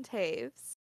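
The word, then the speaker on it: Toews: And Devon Taves, I even said his name right. I'm so proud. I didn't say Devon Toes because that is what I say every single time.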